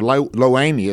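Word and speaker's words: Lo-Ammi: and then the final one, Lo-Ami, or Lo-Ami is the way some people pronounce it.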